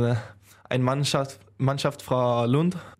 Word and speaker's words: Lund: Aus, wie spricht man das aus? Lund oder Lund? Äh, Lund, ja.